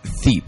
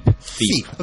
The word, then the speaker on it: THE EP: And how is that T-H-E-E-P? How is that pronounced? – Thiep. – Thiep. Okay.